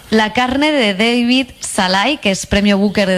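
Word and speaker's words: Szalay: Pues me traje un libro que se llama La Carne, que es de David Snars. Ahora pronunciaré bien su nombre. David Salai.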